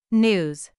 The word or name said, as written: news